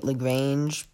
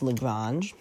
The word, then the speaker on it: Lagrange: Sorry if I was pronouncing it Lagrange before. I can't speak French. But it's Lagrange. It'd be Earth-Moon Lagrange point 1.